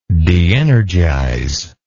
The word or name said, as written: deenergize